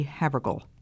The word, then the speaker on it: Havergal: This is your friend, Elizabeth Elliot, talking with you again today about the life of Frances Ridley Havergal. That last name is spelled H-A-V as in victory, E-R-G-A-L, Havergal.